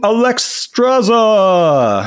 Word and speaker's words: Alexstrasza: Queen Alexstrasza. I think I got that right. – That was the phonetically correct pronunciation right there.